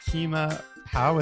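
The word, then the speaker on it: Keema Pav: I am so excited to talk to you about Keema Pow. Is that how you say it? – That's right, yeah.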